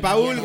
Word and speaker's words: Paul: O sea, No, si es, ponle acento en la U, por favor. Es Paul y va a ser un gran futbolista.